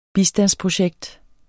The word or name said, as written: bistandsprojekt